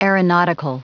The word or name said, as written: aeronautical